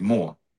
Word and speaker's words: Мо: Я рассказывал уже в других своих эфирах, что вот во Франции, где мы с тобой живем, есть такой замечательный город Мо. Там хрен знает сколько букв, но произносится Мо.